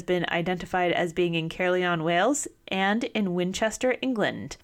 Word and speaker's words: Caerleon: how would you pronounce that? Caerleon? Caerleon? – If it's Welsh, it's probably like Caerleon.